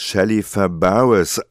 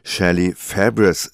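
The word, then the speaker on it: Shelley Fabares: Ich habe ihren Namen bislang immer als Shelly Fabris ausgesprochen, musste mich aber jetzt eines Besseren belehren lassen, dass nämlich die Amerikaner Shelly Fabris sagen.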